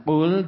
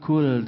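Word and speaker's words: kul (قل): Harusnya kul dengan qaf, ini kul dengan kaf. Keliru, ya.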